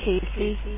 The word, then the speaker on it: Kasey: Sure, it's Casey. – How do you spell it? – K-A-S-E-Y.